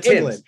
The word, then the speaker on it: Thames: Thames. Yeah. It's pronounced the Thames.